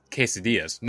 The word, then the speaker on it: quesadillas: No matter how many times I tell her, Mom, it's quesadillas.